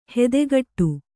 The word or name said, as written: ಹೆದೆಗಟ್ಟು